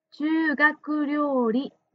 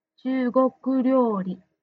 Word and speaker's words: 中国料理: 10 番の答え、中国料理の発音が中学料理に聞こえました。正しい発音は中国料理です。中国料理。